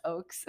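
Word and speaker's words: Oakes: Yeah, it's Oaks. – Oaks? – Oaks. – It looks like ox.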